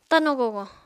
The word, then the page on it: tano-gogo: Apatani